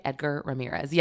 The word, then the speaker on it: Edgar Ramirez: So he is in this show, and it also stars Edgar Ramirez. Did I say that right? Edgar Ramirez, yes.